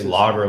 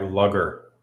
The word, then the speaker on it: lager: Um, Americans say lager like lugger.